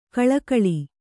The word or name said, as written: ಕಳಕಳಿ